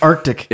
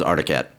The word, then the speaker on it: Arctic Cat: Because it's Arctic Cat. – It is Arctic Cat, but I think everybody says Articat.